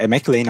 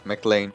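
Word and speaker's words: McKellen: É McLean, eu acho a pronúncia. – É, McLean.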